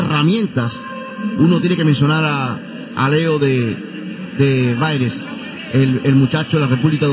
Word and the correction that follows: De Vries: De Vries. Para no complicarlo. – Sí, porque aquí decían Baires, pero De Vries entonces, ¿no?